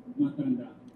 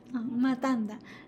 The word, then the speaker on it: Matanda: Matanda. – Matanda. Yeah. You, I've been telling you, do not pronounce T like English T or American T. No. Ta, ta. Yeah. Matanda.